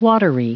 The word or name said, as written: watery